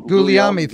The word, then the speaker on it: Guglielmi: You were 4-0 against the Trojans, which, according to my record keeping, only Ralph and I can't ever pronounce Ralph's last name. – Guglielmi. – Thank you.